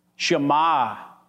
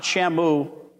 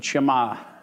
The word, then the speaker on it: Shema: How do you say it? Shema. Shema. Not Shamu, but Shema.